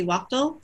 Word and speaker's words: Waughtal: I'm on Twitter and Instagram, both under Marcy Waughtal. So just at Marcy. Last name is spelled Waughtal.